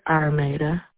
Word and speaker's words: Armada: Armada Hardy. – Can you spell it for me? – A-R-M-A-D-A. – A-R-M-A-D-A.